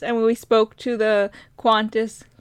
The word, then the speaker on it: Qantas: I think it's spelled Qantas, but they say it because of their awesome accents, Qantas. Like, weird, like, trying to put a U in there, but they don't. Qantas.